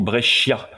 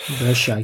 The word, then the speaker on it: Brescia: Bah, il a sa bio pour terminer cette conversation, on dit Alfonso Brescia. – Brescia, excusez-moi. – Parce que le SCIA se prononce che comme dans le verbe chiare, qui veut dire skier.